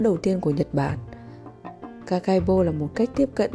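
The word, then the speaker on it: Kakeibo: Kakaibo, phát âm là Kakaibo,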